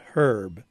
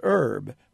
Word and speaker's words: herb: the Roman pronunciation from which it came is with an H, herb. The English want to call it herb, which is dropping the H.